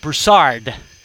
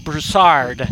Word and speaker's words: Broussard: So I had to say Broussard. Karlo Broussard.